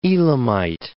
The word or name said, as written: Elamite